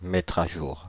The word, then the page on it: mettre à jour: [mɛ.tʁ‿a ʒuʁ]